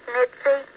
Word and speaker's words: Mitzi: Hi, my name is Mitzi, and I'm from the Petersburg area.